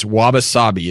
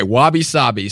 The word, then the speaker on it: wabi-sabi: It's wabi-sabi. Is that right? – I believe the Japanese might say wabi-sabi, but yes, go ahead.